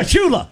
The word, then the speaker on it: Shula: It rhymes with moolah. – Yeah, Shula.